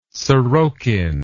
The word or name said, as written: sorokin